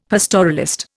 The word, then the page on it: pastoralist: Indian